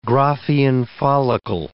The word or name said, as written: graafian follicle